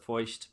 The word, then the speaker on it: Feucht: S-E-A-N. – F-E-U-C-H. – E-U. – C-H-T. – C-H-T? Feuch? – I pronounce it Feucht, but Feucht.